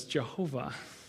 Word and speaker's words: Jehovah: Consequently, how you pronounce that is Jehovah, the nonsense word.